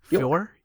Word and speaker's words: Foer: So the winner for this month's trivia question is, and I apologize if I mispronounced the last name, is Joshua Four Fear. – Four. – It's F O E R. – F O E R. – Four. Maybe. – Like he's golfing.